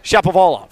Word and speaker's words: Shapovalov: Dennis Shapov. How do you pronounce it, Stone? – Try it. I want to hear you try it. – Shapovalov. – Bingo. – Okay, good.